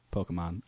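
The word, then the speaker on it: Pokémon: I did it for Pokemans. – Uh-huh. – Pokemon. I'll say it right.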